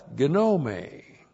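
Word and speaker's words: gnome (γνώμη): That's gnome. So the key to didomi is knowing this noun. G-N long O-M long E. Gnome. You look at it and see gnome, but it's gnome.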